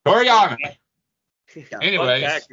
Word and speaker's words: Toriyama: You said it wrong again. – God damn it. – Don't disrespect the good goddamn name of Akira Toriyama, sir. – Toriyama. – Say it with me. Tori? – I'm not saying it.